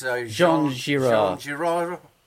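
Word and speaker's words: Jean Giraud: It's Jean Girard. – Yeah. They don't pronounce the final letter.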